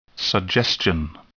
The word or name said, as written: suggestion